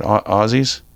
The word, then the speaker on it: Aussie: Back Saturday, hopefully at least a night in California before the big one to Aussie land. Yeah, Aussie, is that how you say it? Aussies, yeah, with a Z, right?